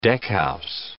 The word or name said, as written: deckhouse